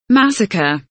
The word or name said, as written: massacre